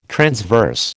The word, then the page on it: transverse: American English